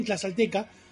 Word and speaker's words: tlaxcalteca: Por cierto, Carlitos pronunció bien tlaxcalteca, como decía Magnus, no siempre se pronuncia igual. Yo no me acuerdo cómo lo pronunció y no sé si lo tengo pronunciado bien. – Sí, la X era X, aparentemente.